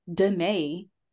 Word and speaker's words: Denae: And so when you have a name like Danae and it's spelled with all the vowels it has in it, people like to act confused. People have called me like Danielle, Dana, Diane.